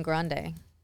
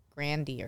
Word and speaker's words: Grande: You know, her name's not even Grande. I heard her say on a talk show that it's pronounced Grandy or something.